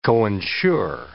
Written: coinsure

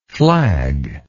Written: flagless

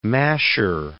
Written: masher

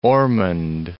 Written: Ormond Beach

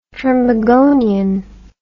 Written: trinbagonian

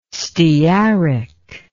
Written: stearic

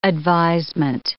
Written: advisement

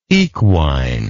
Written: equinity

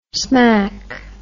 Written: smak

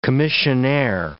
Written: commissionaire

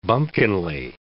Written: bumpkinly

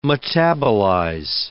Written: metabolize

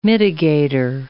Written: mitigator

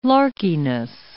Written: larkiness

